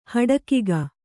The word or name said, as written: ಹಡಕಿಗ